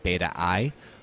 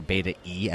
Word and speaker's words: Beta-i: the co-founder and CEO at Beta I, or Beta E as it's correctly pronounced.